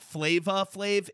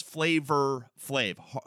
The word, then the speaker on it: Flavor Flav: Everyone wants, and even the article I recently read his name in, it's not Flava Flav, it's Flavor Flav. It's O-R, not A.